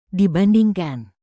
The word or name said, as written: dibandingkan